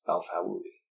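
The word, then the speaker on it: El-Faouly: That's not how you... Fauli. That's how it is. El Fauli.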